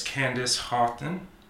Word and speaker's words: Candice Haughton: And my guest, her name is Candice Haughton.